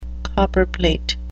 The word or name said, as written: copperplate